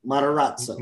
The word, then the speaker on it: Matarazzo: Maserato, is that how you say her name? – Matarazzo. – Yes, something like that.